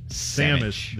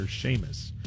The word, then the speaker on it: Samus: I think it's Samus is what I was not Samus. Saying Samus, the character from Metroid. I was not saying her name correctly. I kept calling her Seamus.